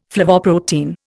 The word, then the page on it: flavoprotein: Indian